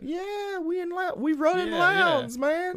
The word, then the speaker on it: Lowndes: It rhymes. Human misery abounds in Lowndes.